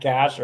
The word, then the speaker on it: Gash: So John Schultz asked this, is it pronounced Gat, Gash, or Gatch? I asked you this at the beginning of the cycle because everybody uses one of those three pronunciations. I know you've asked them in the past, but I still don't think we've had a clear, like, pronunciation for Avery. – It's Gash. – It's Gash, like the second one? – Yes.